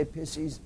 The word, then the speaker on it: Pisces: I'd say Pisces, which is the proper pronunciation, but most people cringe.